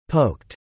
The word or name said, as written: poked